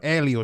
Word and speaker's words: Elio: Elio.